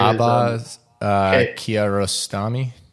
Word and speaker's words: Abbas Kiarostami: Abbas Kiarostami.